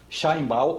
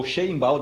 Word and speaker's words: Sheinbaum: Cláudia é a presidente do México, Cláudia Sheinbaum, ou Sheinbaum, dependendo da vontade que você tenha para pronunciar um nome que é judeu.